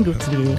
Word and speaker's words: Guthrie: È Gwen Guthrie. – Esatto, Gwen Guthrie.